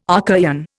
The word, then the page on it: Archaean: Indian